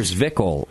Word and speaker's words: Zwickel: Well, we're drinking your Zwickel lager right now. Am I saying that right if I was kind of a German? – Yes.